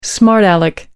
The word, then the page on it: smart aleck: British English